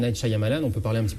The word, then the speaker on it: Shyamalan: Mais on vous a pas vu uniquement sur M6, ensuite France 2, avec l'évocation du film Signe de Night Shyamalan, enfin je sais pas si... – Shyamalan.